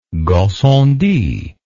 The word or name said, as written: Gassendi